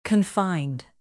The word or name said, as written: confined